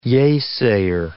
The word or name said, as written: yea-sayer